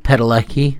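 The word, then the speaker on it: Padalecki: Jared Paledecki.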